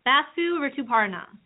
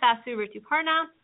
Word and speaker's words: Basu Rituparna: You can follow me at Basu Rituparna.